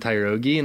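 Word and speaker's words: Tyrogue: and then all of a sudden this little Kyrogi, is that how you pronounce his name? Kyrogi? I'm pretty sure. – Yeah. – He just Tyrogi, the little fighting Pokemon.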